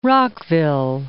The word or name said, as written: Rockville